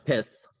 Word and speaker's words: PiS: Pronounced piss. – Piss, yes.